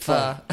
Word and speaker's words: pho: I like the way you say it, pho. – So I think that's the correct pronunciation.